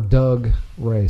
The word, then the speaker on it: Dougray: It's literally Doug Ray. – Dougray. – As one word. – Yeah. – Oh, no. – It's Doug Ray.